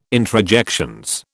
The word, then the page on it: introjections: British English